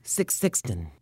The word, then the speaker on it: Sixten: It's Sixten. – Come again? – Say Sixten really fast, and you'll be close enough.